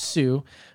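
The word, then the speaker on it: Hsu: His name is Stephen Sue, or Stephen H-S-U. I think it's just Sue, right? Silent H.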